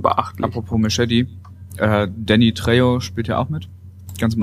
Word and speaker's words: Trejo: Trexo ausgesprochen? – Trejo. Nee, Trejo. Im Übrigen Trejo.